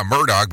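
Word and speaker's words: Murdaugh: Yeah, Murdoch, but that's not the way that it looks.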